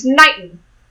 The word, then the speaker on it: Knighton: named Knighton, which is spelled with a K, which is important. – Knighton Day. – It's like, yeah, it's like a knight in shining armor.